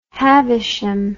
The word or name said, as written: havisham